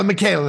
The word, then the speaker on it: Michaela: And I'm not sure how you pronounce it. Michela, how it's written there? – Michaela, that's right.